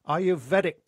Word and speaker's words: Ayurvedic: Ayurvedic principles.